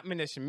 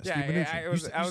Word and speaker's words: Mnuchin: That's his name, not Mnuchin. Mnuchin. – Yeah, Steve Mnuchin. You said it right.